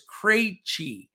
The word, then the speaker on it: Krejci: Vit Krejci, who we talked about, that was one I couldn't pronounce for a long time until one of our listeners said it's Krejci.